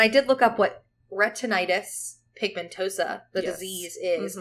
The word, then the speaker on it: retinitis: she suffers from retinitis pigmentosa, is that how you pronounce it? – Yep. – And that's exactly what Trinity... – Retinitis. – Retinitis. That's correct.